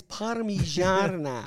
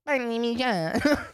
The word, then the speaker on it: parmigiana: That's because you pronounce... It's parmigiana. – Parmigiano. – It's polos parmigiana. – You have to cut off air supply to your nose. And say, parmigiana.